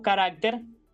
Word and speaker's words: carácter: Cuando tiene este significado, es frecuente escuchar una pronunciación impropia de ese término. Y escuchamos, por ejemplo, decir, me sobra un carácter en el tweet. Aquí lo adecuado hubiese sido decir, me sobra un carácter en el tweet. Así que cuando carácter significa signo de escritura o de imprenta, mantiene esa misma pronunciación. Carácter y no carácter.